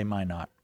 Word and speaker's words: Minot: I'm going to say Minot. We'll see what Valentin says. Did I pronounce it correctly or wrongly? – Yep, you got it.